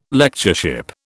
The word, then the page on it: Lectureship: British English